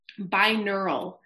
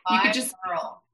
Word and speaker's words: binaural: So going back to the first thing you said, how do you pronounce that exactly? Some kind of beats, premorbal? No. – Binaural. – Binaural.